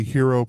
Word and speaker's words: Hiro: In reality, hero protagonist. I get it. It's because it's spelled, for people who don't realize why I'm laughing, H-I-R-O protagonist. So I'm like, it's the Japanese. – Like hero from Heroes.